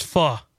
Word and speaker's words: pho: Is it pho? – It's pho. – Pho. Okay.